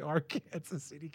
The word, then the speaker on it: Arkansas City: And it's spelled Arkansas City, but don't say that. They get mad at you. – Okay. – So you got to say Ark, Kansas City, Kansas.